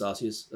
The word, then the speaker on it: Arceus: I think I was more interested in Legends Arceus at the time.